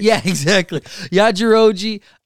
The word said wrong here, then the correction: Yajirobe: I'm saying his name wrong, but cut off Yajirobe.